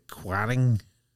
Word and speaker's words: Quiraing: Now at Uig, if you there's a road signed for the I think they pronounce it the Quiraing, right?